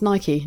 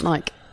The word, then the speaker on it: Nike: Oh, so it is pronounced Nike, not Nike.